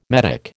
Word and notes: medick: American English

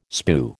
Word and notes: spue: American English

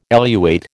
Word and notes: eluate: American English

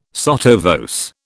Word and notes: sotto voce: British English

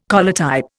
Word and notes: collotype: Indian

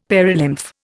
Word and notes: perilymph: Indian

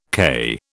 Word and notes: CAI: British English

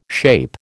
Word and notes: SHAPE: American English

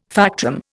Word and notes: factum: Indian

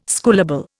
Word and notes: schoolable: Indian

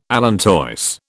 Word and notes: allantois: British English

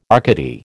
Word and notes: Arcady: American English

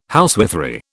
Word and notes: housewifery: British English

